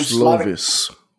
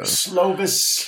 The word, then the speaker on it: Slovis: But freshman, man, how do you say his name? – Slovis. Yeah. – Slovis. – Keaton Slovis.